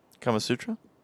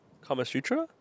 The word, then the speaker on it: Kama Sutra: No, what's the Karma Sutra? – I don't know. I was about to ask you what the heck that is. – I think I said it right. – Kama Sutra? – Kama Sutra? – I think so.